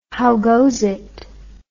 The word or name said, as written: howgozit